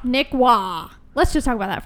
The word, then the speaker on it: Roy: It really irritates me because it's spelled R-O-Y. That is Roy. We are in America. Okay. Also, though, I'm just like, Marchessault, you don't pronounce a W with his R.